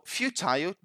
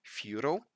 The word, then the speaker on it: futile: So, futile, just a quick remark, that would be the British pronunciation, right? The American pronunciation would be something like futile.